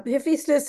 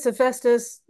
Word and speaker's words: Hephaestus: And so when he gets to describing Legba and Hephaestus, is that how you pronounced it? – Oh, yeah, Hephaestus, Hephaestus.